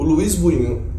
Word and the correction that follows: Buñuel: Luiz Bunho, Buñuel, falei certo? – Sim. Falei Falou, Buñuel. Sim, como Ele tem o cão andaluz como o filme surrealista por excelência.